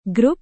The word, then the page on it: grup: Indonesian